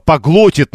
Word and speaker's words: поглотит: Да, поглотит надо говорить... Нет, поглотит надо говорить. И слово из трех слогов рассказывает нам просто Алла. По-гло-и-тит. Транскрипция слова тоже Аллой приводится. В общем, ударение падает на третий слог.